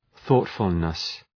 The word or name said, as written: thoughtfulness